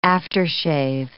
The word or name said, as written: aftershave